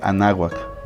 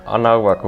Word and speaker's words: Anáhuac: De manera paralela a esto, en México, Jacobo fundó el Laboratorio de Psicofisiología en la Universidad Anáhuac. Perdón, lo dije mal. Anáhuac, güey.